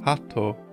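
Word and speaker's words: hato (鳩): This is likely a pun based on the fact that the kanji is pronounced hato, a similar sounding word to heart.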